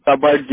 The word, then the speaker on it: cupboard: और अब बात करें तो आपका आ जाएगा कबर्ड, जिसको कहते हैं अलमारी, ठीक है, CUPBOARD, कबर्ड, ठीक है।